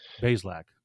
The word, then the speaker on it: Bazelak: So you brought up last year we saw Michael Penix Jr. This year the Mizzou transfer is Connor Bazelak. Am I saying that correctly? – It's Bazelak.